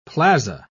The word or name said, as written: plaza